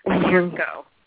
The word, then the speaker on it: Pirkko: Pirkko.